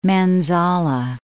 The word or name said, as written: Manzala, Lake